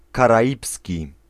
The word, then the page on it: Caribbean: American English; /kæɹɪˈbiːɪn/; /kæɹɪˈbiːən/; /kəˈɹɪbiːən/